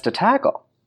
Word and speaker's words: De Tagle: And then Anna Maria Perez de Tagle. – Yes. – Is that how you say it? De Tagle.